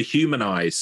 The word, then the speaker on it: Humnize: So for people listening while they're out walking the dog or driving in the car, the company name is spelled H-U-M-N-I-Z-E. So there's no A in the middle of there.